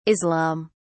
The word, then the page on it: Islam: British English